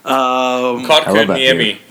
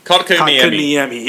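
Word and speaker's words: Kotkaniemi: It's like Kolkainemi. – Kotkainemi. Kotkainemi.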